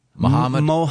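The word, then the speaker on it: Mahomet: He is on his way to, is it pronounced Muhammad? – I believe so. – Muhammad.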